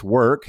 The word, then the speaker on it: Werk: We're first going to talk about Work. That's spelled W-E-R-K because it's Europe and it's cool. Estonia-based Work,